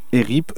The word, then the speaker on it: ERIP: ERIP, E-R-I-P...